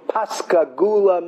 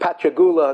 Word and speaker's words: Pascagoula: He comes from Pascagoula, Mississippi. For many years, we used to pronounce it Pachagula until somebody corrected us. But Pascagoula or Pachagoula, Lamaisis is Hainu Hach.